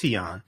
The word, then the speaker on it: Tignon: Tion laws, T-I-G-N-O-N, it's pronounced Tion.